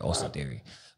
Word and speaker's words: Austin Theory: Yeah, I did say it right, Austin Theory.